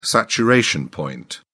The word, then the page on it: saturation point: British English